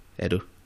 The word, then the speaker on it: Edo: How did you pronounce it? Is it, you said Edo? – Yeah, Edo.